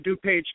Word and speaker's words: DuPage: Well, the young men and women standing over there today to DuPage County Honor Guard.